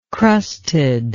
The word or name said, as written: crustedly